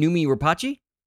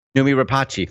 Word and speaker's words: Noomi Rapace: Oh, you mean Noomi Rapace? – Noomi Rapace, thank you.